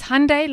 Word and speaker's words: Hyundai: It's Hyundai like Sunday.